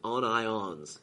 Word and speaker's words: Onions: Apparently, pronounced it on ions. He was from Birmingham. He said on ions, not onions, but he looks like onions.